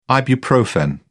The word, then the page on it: ibuprofen: British English